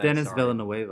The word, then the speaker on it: Denis Villeneuve: But in 2049, he does the director in this, Denise Villeneuve. – Is that how you say it? I thought it was Denis Villeneuve. – If I mispronounce it's Denis Villeneuve, I'm pretty sure.